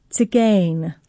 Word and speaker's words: Tzigane: We are going to start off with a piece by French composer Maurice Ravel, his piece Tzigane, kind of like tzatziki, I was reminded when I looked up the pronunciation.